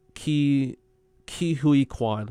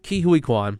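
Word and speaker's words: Ke Huy Quan: Ki Hui Kwan. Okay. Ki Hui Kwan. That's how you say.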